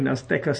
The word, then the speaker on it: Azteca: So, this game between Houston and Oakland is taking place in Azteca Stadium in Mexico City, in Mexico.